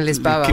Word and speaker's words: Lisboa: I'm just going to keep saying Lisboa, which is how they say it in Portugal.